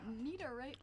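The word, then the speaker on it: Niederegger: Niederreger. – Niederreger. – Look, that's the name. Niederreger. – Wait, can you speak German? – Because it's like Schwarzenegger.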